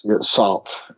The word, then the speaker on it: Sartre: There's one more quote here from Jean-Paul Sartre. I don't know if I said that right. But you did. It's Sartre. Okay, Sartre.